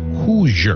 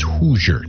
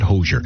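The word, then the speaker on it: Hozier: his name H-O-Z-I-E-E-R, but he says you can call him Hoosier, because that's the way he likes it pronounced. Hoosier, not Hoosier.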